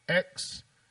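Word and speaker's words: X Æ A-12: Rogan then asked how to say it, to which Musk responded, I mean, it's just X, the letter X, and then AE is like pronounced ash, and then A12 is my contribution. So... X-A-12.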